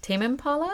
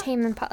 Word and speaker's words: Tame Impala: Tame Impala? – Tame Impala.